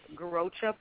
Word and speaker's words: garrocha: And here as well, but it's called the Garrocha pole, and they're usually 13 feet long and wooden, and it's used as a tool to enhance your horsemanship.